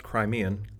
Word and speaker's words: Crimean: It is pronounced Crimean, like Crimea River, and given its history, that may be appropriate.